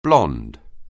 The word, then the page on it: blond: anglais